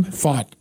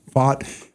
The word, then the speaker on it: Pfaadt: Pfaadt, is that how you pronounce his name? – Pfaadt. – Pfaadt. – In fact, if you're doing like a European saying of fat.